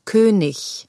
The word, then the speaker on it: König: Dies wird meistens wie ich ausgesprochen. Es heißt also wichtig, König, wenigstens.